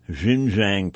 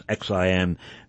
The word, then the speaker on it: Xinjiang: resource and mineral resource rich and petroleum rich Xinjiang province, and I believe I am mispronouncing that, that's Xinjiang.